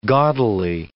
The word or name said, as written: gaudily